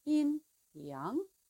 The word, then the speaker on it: yin-yang: In English, we tend to say yin-yang, or yin and yang, or yin and yang. But the pronunciation is lighter and softer. Yin-yang.